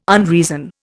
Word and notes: unreason: Indian

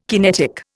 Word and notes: kinetic: Indian

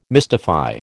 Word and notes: mystify: American English

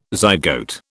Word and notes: zygote: British English

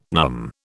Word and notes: numb: British English

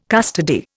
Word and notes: custody: Indian

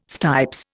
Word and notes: stipes: Indian

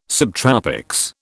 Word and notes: subtropics: British English

